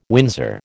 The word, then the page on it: Windsor: American English